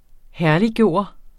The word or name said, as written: herlig